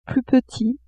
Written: plus petit

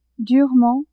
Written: durement

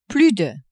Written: plus de

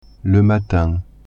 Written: matin

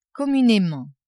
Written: communement

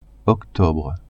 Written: octobre

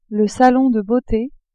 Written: salon de beaute

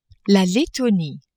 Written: Lettonie

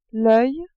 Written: oeil

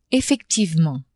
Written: effectivement

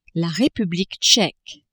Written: Republique tcheque

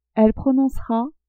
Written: elle prononcera

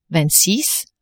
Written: vingt-six

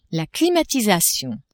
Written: climatisation

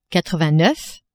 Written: quatre-vingt-neuf

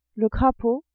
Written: crapaud

